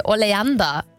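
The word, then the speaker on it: Oleander: Almancası nedir demiştim. Tam telaffuzunu düzeltin lütfen demiştim. Gorca yazmış, diyor ki oleanda diye okunuyor dedi.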